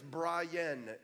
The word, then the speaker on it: Brian: It was so loud, my mom, so my name is Brian. It's two syllables.